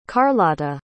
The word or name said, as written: karlotta